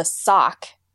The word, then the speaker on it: SOCC: The station is called the SOCK, which is spelled out as the SOCC, and it stands for the Sounds of Colorado College. But I was interested to hear that they pronounce it the SOCK. I hadn't anticipated that when I saw it written out.